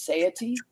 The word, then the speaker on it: satiety: And protein does improve your, I think the word is pronounced satiety.